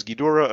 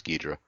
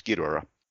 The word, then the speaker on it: Ghidorah: And for those who might correct me, it was Ghidorah originally. When they brought it over to the US, it was Ghidra. So I'm going with Okay. – the original pronunciation, which was on the version I watched, Ghidorah.